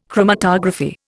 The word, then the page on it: chromatography: Indian